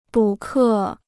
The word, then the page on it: 补课: bǔ kè